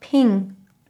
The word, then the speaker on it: PNG: The creators of the PNG image file format wanted it to be pronounced ping.